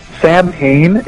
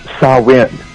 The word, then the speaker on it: Samhain: Is that Samhain is actually pronounced Samhain. – Yeah. – In the Celtic.